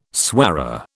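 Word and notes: soirée: British English

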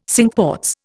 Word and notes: Cinque Ports: Indian

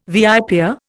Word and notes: viper: Indian

